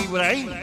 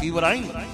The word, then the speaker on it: Ibrahim: Ibrahim. Sí, se pronuncia así ese nombre, Egipto, ¿ver? Ibrahim.